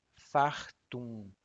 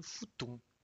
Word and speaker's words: fartum: A palavra correta é fartum, assim. Mas no Brasil nós pronunciamos muito futum e significa uh, um cheiro muito, muito ruim.